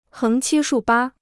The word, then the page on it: 横七竖八: héng qī shù bā